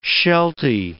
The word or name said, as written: shelty